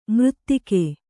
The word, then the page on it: ಮರೆದೆಗೆ: maradege